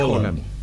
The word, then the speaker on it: Haaland: Erling Haaland. – ¿Haaland? – Sí. – ¿O Haaland? ¿Cómo se dice? – Sí, se dice Haaland.